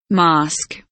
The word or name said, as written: mask